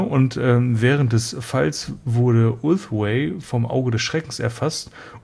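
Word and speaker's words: Ulthwé: Heute erst gelernt, es wird wohl Ulthway, so wie der Weg im Englischen, ausgesprochen.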